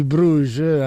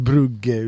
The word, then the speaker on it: Brugge: Questo era il 23enne di Bruges, anzi direi di Brugge, visto che comunque è fiammingo, Charles de Ketelaer, che comunque ha imparato che in Italia la parola scudetto non si pronuncia.